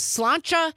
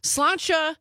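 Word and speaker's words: sláinte: Amy from Madison texting in on the text line saying the correct pronunciation of the Irish cheers to your good health is slancha. That's how I've always pronounced it. So slancha.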